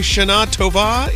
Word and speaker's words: Shana Tova: I think you say Shana Tova. Isn't that how you say it? – Shana Tova. – There you go.